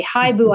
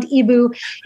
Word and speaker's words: eeBoo: i like the word for owl in french which is ibu but they spell it h-i-b-o-u but we didn't want people to say Hi boo i actually like the sound ibu